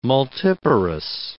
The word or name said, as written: multiparous